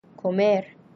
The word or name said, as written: comer